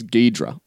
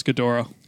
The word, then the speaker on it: Ghidorah: I thought it was Ghidorah. – It is Ghidorah. Well, as far as I'm concerned, I'm putting my foot down. I'm saying it's Ghidorah.